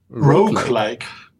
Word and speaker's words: Roguelike: Roguelike.